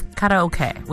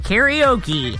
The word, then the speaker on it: karaoke: The word of the day is karaoke, which is how they pronounce it in Japan. Here we say karaoke.